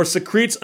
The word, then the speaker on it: secrete: By the way, the word secrete is pronounced that way, and it's like the word secret with an E, but it's not like something that secretes a fluid or something.